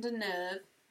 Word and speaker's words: Deneuve: It is a Roman Polanski movie starring Catherine Deneuve.